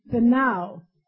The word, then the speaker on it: Peniel: And how do you say it in English? Peniel? Is that the way you say it? That's the way we say it. For he said, Peniel.